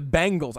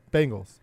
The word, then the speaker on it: Bengals: I think the problem is to call them the Bengals. How do you say it? – The Bengals. Bengals. – Yeah, they're not the Bengals.